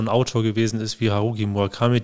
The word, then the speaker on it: Haruki Murakami: Kensaburo Oe und Haruki Murakami.